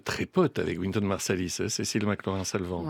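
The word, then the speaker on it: Cécile McLaurin Salvant: Cecile McLaurin, Salvant, Sherman Irby, arrangement and alto saxophone.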